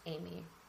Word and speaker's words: Eimi: and Amy is spelled, where is it? E-I-M-I, which is Greek for I am, but it's pronounced Amy. Anyway, so if you're like looking it up and you can't find like A-M-Y, like where is it?